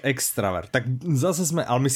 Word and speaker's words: extravert: Ale tady ten vzkaz od, od Hodzini pobavil, jednak nás upozornil na chybu, že vyslovujeme extrovert, a je to extravert. Jako, ex, extravert? jako extravert nám napsal, že je eh. extravert a že to říkáme špatně, tak bych vlastně chtěla veřejně opravit.